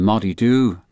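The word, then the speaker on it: Moddey Dhoo: And I think there was one of the scene where they went to Peel Castle and a Moddy Do. Now, some people might say I might have said that wrong, but that's the way the northern people say it.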